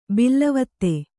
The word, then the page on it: ಬಿಲ್ಲವತ್ತೆ: Kannada